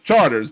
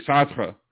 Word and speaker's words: Chartres: That's Charters, but it should be Chartres because it's French. It should be Chartres. – I'm sorry. Say that again. – Chartres. That's the French pronunciation of it.